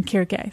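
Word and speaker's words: Kyrke (Κίρκη): And technically in the Greek, it would have been a hard C, so it would have been Kyrke.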